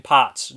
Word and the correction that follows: Poots: name wrong, but, or Poots.